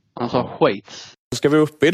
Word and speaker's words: Schweiz: Eh, hur man uttalar Schweiz. Inte som eh, Gustaf Fridolin då, Schweiz. Utan det uttalar man som, som, som jag säger, Schweiz, Schweiz. Schweiz.